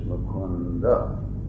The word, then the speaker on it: Mukunda: In Sanskrit, the when you see Sanskrit in Roman transliteration, for example, here we have Mukunda. So it is Mukunda. It's not exactly fully pronounced, but it is there, Mukunda. But if it were any of the vernacular languages, Mukund, they don't pronounce the A.